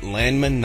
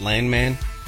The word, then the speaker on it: Landman: It is Nate Landman, not Landman. He is not Billy Bob Thornton.